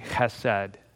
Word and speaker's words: chesed: And this power he has is a substantial power, and it is a power, I said earlier, that is governed by a Hebrew word that is pronounced somewhat crudely, chesed. Chesed.